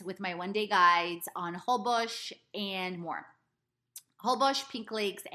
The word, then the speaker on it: Holbox: which is spelled Holbox but pronounced Holbox, including incredible adventures.